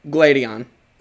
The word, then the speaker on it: Gladion: But then Sylveon, I'm saying his name right? – Gladion. – Gladion, I combined him and Silver for some reason.